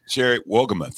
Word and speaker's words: Wohlgemuth: Welcome to the show, uh, Sherry Wohlgemuth.